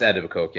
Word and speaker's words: Etobicoke: But is it Etobicoke? – Oh, they – yeah. Etobicoke. – And am I saying that right, yeah, or am I slaughtering? – Yeah, no, it's Etobicoke, yeah.